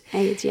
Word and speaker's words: Eid: Eid, yeah.